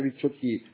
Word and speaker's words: Ricciotti: Sì, no, anzi lo conosco bene e non capivo l'accento. Rudy Ricciotti. Sì, Rudy Ricciotti, in realtà è Ricciotti, in realtà è abbastanza italiano.